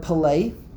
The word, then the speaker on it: pelei: When you take exactly that word and you pronounce it differently, like not a pella, but pelei, then it means to separate.